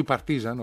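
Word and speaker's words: Partizan: Και να λήξει παράλληλα ισόπαλο το νης Παρτιζάν ή με νίκη της Παρτιζάν. Λέει ο φίλος, ή Παρτίζαν, όπως λένε οι Σέρβοι, γιατί Παρτίζαν τι προφέρουν.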